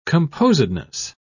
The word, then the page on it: composedness: British English